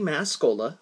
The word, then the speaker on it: Mascola: which again is Andy Mascola. My last name is spelled M-A-S as in Sam, C-O-L-A as in Coca-Cola.